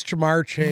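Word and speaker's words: Ja'Marr Chase: Will you say it properly for me, please? – Who is Jamar Chase? – Very good. There's a point.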